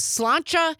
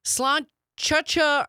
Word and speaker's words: sláinte: Amy from Madison texting in on the text line saying the correct pronunciation of the Irish cheers to your good health is sláinte. That's how I've always pronounced it. So sláinte, sláinte, or whatever it is.